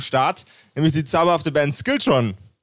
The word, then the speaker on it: Skiltron: Is, it, uh, is did I pronounce that right? That's perfect. I uh, um, I thought it would have a more um, Scandinavian uh, ring to it when I say it, like Skilltron.